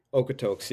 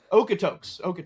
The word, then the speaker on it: Okotoks: But then, you know, you got your first full AJHL season with the Okotoks Oilers. Is that right? Am I saying that right? – Okotoks. Yeah. – Okotoks.